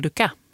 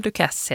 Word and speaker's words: Dukas: Den uppmärksamma lyssnaren kanske hörde att jag uttalade hans namn med tyst S. Paul Dukas förut. Det är fel. Paul Dukas heter han.